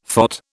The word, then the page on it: phot: British English